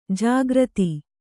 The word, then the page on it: ಜಾಗೃತ: Kannada